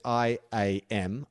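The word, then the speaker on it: Iam: And then I am, well, it's spelled like Ian, except it's I A M. I'm uh, produced pronounced I am.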